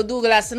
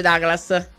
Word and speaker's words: Douglas: Allora Spalletti sbaglia, diteglielo Douglas. No, raga, è brasiliano, non è americano e quindi non si legge Douglas.